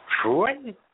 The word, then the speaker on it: Fruin: Okay, here's the judge's name: Judge Richard Fruin Jr. That last name is spelled F-R-U-I-N. – Fruin? – F-R-U-I-N. – F-R-U-I-N. – I said Fruin. – F-R-U-I-N.